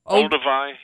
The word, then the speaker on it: Olduvai: Olduvai.